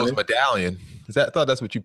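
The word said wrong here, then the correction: Medellín: No, it's not Medallion. Don't be an American. – William thinks he can pronounce everything, doesn't he? – What's it called? Medellin.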